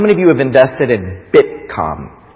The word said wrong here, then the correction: Bitcoin: Did I even say it right? Bitcom. Anybody, is it com or con? Coin. Bitcoin.